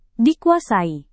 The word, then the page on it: dikuasai: Indonesian